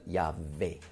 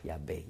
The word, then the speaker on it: Yahweh: Und das Beta weich ausgesprochen gibt dann Yahweh, Yahweh, Yahweh. Ja? Und damit ist klar die Aussprache, wie die korrekt ist.